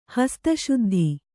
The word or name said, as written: ಹಸ್ತಶುದ್ಧಿ